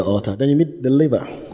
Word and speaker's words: laver: Say the laver. It is spelled L-A-V-E-R, the laver.